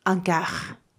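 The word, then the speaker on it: un car: で、先ほどご紹介した名詞のキャーは、看護バス 1 台で、えー、男性名詞なので、アンキャー、アンキャーと発音します。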